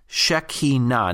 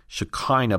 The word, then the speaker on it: Shekinah: S-H-E-K-I-N-A-H. Shekinah. Now, sometimes it's mispronounced as Shekinah, but the emphasis is on the ultimate or final syllable. So it's not Shekinah, but Shekinah, right?